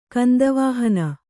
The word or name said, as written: ಕಂದವಾಹನ